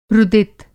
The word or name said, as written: रुदित